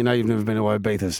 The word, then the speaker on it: Ibiza: It's Ibiza.